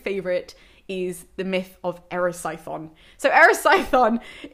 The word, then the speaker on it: Erisichthon: And as far as I'm aware, it's Erisichthon. If you're Greek, then please correct me in the comments below. But my teachers have always said Erisichthon to me.